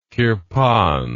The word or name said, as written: kirpan